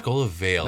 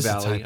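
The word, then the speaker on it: Skola Vale: Oh, Scola Vale. Nice. I'm sorry. Yes, it's a Scola Vale. I saw Vale, I read Valley, I'm not sure why.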